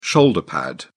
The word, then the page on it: shoulder pad: British English